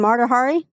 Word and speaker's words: Mata Hari: Mata Hari? – Yes.